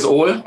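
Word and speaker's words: øl: Ja, es ist tatsächlich Öl, länger ausgesprochen.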